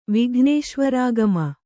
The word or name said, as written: ವಿಘ್ನೇಶ್ವರಾಗಮ